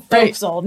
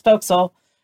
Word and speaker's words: fo'c'sle: No one pronounces fo'c'sle correctly because that's not the way it's spelled. It's spelled forecastle. So no one ever says it right. But anytime I'm with my kids on a boat and they're reading the sign, I'm like, nope, that's fo'c'sle.